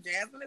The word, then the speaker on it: Jasmyne: Jasmyne is my first.